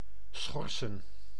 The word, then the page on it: interdire: /ɛ̃.tɛʁ.diʁ/